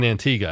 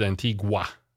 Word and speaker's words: Antigua: And that's the correct pronunciation of Antigua, by the way. And I know that from my friend Terry correcting me earlier. So thank you for that. I think I had said Antigua, which is totally incorrect.